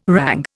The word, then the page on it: rang: Indian